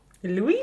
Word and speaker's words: Louis: Louis? – Du uttalar alltså inte S-et på slutet.